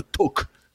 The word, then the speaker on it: talk: Alors, le talk euh, du jour, évidemment, et je prononce bien le L de talk pour trigger, monsieur le prof qui déteste quand on fait ça, parce que en bon anglais, on devrait dire le talk.